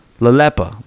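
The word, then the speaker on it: Lelepa: Cruisers, get ready for the Perfect Day at La Pela. Wait, hold on. I think I know how to say this. La Lepa.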